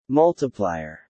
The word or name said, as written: multiplier